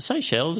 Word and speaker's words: Seychelles: The Seychelles, is that just pronounced? – Seychelles.